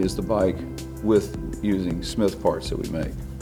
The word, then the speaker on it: Cmith: And that's C-M-I-T-H. – Yes, for sure. It's C-M-I-T-H, pronounced Smith, not C-Smith or Smith. It's just Smith.